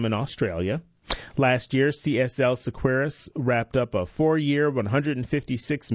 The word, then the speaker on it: Seqirus: CSL Sequeiros, that's spelled S-E-Q-I-R-U-S, has appointed Jonathan Kegerize as vice president of manufacturing, where he will lead a team of more than 950 employees.